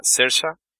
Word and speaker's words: Saoirse: Sí, es como la otra vez que escuché cómo se pronuncia Saoirse Ronan, que se pronuncia Sersha, y dije, ¿cómo un nombre?